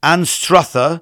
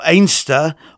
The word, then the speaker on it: Anstruther: Now, Anster, A-N-S-T-E-R, is a nickname for the nearby fishing village of Anstruther, or Ainster, or in this case, Anster. Quite confusing, I know. Anstruther is spelled A-N-S-T-R-U-T-H-E-R.